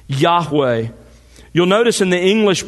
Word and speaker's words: Yahweh: a translation of God's personal name, pronounced Yahweh.